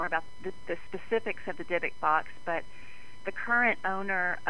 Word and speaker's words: Dybbuk: and I'm always saying this wrong, but the Dybbuk box. – That's right. Correct.